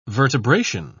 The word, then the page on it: vertebration: British English